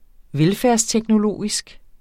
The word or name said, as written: velfærdsteknologisk